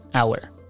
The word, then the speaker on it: our: our اولا تلفظش به این شکل هست آر تلفظ نکنید در حالی که البته من شنیدم که بعضی حتی توی آمریکا آر هم میگن توی سری لحجه ها ولی لحجه ای که شما باید بگیرید این یعنی لحجه استانداردی که بگیرید بهتر هست که تلفظ کنید اور